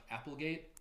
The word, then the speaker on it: Applegate: I believe it's pronounced Applegate. – Did I say that? – Yeah, like Watergate.